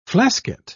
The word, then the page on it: flasket: British English